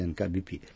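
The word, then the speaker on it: ТНК-BP: Но замечательным образом первая часть читается русскими буквами, а вторая латинскими. Это нефтяная компания, это «НК-BP».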